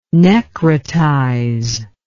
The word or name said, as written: necrotize